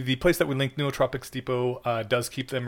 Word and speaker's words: nootropics: like apparently the pr- correct pronunciation is nootropics. Like you gotta like it's hmm. pr- pronounce both O's, and that just you nobody, take your nobody does that.